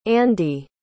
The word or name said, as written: andee